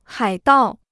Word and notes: 海盗: hǎi dào

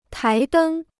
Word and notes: 台灯: tái dēng

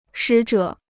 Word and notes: 使者: shǐ zhě

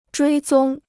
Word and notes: 追踪: zhuī zōng

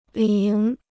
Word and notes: 铃: líng

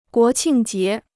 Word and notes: 国庆节: guó qìng jié

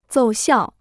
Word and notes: 奏效: zòu xiào

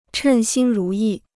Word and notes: 称心如意: chèn xīn rú yì

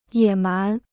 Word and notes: 野蛮: yě mán

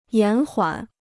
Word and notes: 延缓: yán huǎn

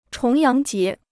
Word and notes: 重阳节: chóng yáng jié

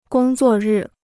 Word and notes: 工作日: gōng zuò rì

